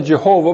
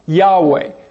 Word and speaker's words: Yahweh: Some people pronounce that Jehovah, but it's more accurate to call that Yahweh.